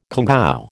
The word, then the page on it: congou: American English